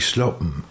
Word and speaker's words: Slappen: Ach, Slappen. – Ja. – Genau, ja. Slappen. Genau. Ja, es gibt im Plattdeutschen immer das geht immer so ein bisschen zwischen A und O, wird das ausgesprochen.